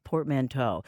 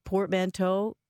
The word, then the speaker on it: portmanteau: Did I pronounce portmanteau correctly? What is it? – I believe you did.